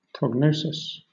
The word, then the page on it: prognosis: British English